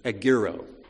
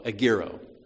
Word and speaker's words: egeiro: In every instance, this word raised is from Strong's 1453, which my terrible Greek pronunciation would be egiro, spelled E-G-E-I-R-O, egiro, which simply means to awaken.